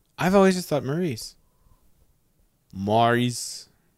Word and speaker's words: Maurice: Is it Morris or Maurice? – Maurice. I think it's Maurice. – It's spelled Maurice, but every time I see that, I don't want to say Maurice. I want to say Morris still.